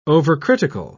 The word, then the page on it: overcritical: British English